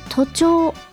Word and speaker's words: 徒長: 学校の生徒さんの徒という漢字、行人弁に走るという字ですね、の漢字を書いて、短い長いの長いという漢字を書いて徒長と読みます。